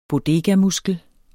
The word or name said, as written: bodegamuskel